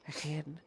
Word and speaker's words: Ayin: Ayin, which is sometimes, I know I'm not pronouncing that properly, it's more of a guttural sound, Ayin, which is sometimes described as having two eyes that connect to a common optic nerve that leads to the brain.